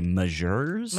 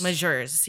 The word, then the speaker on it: Magers: How do you spell it? – It's M-A-G-E-R-S. – M-A-G-E-R-S. – Yeah. – And people would say Majors? – Majors, yeah.